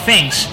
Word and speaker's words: Zhenx: Esta idea se llama Thanks. No quiere decir gracias en inglés, no se escribe así. Se le parece, o al menos igual es lo que intentan, ese Thanks, ¿no? Pero esto se escribe Z-H-E-N-X, así que es algo parecido, quizá han hecho un juego de palabras, pero bueno.